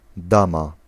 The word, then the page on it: dame: France; [dam]